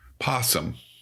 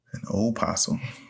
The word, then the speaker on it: opossum: An opossum. – Is the only one who's correct. – Oh, come on. It's Arkansas. We don't pronounce half the vowels. – A possum is a completely different marsupial found in Australia.